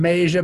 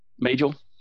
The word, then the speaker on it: Majel: Yeah, you know, it's all, yeah, exactly, exactly, like, the computer can't voice all, what's her name, Maja Barrett or whatever. You pronounce it. – Majel.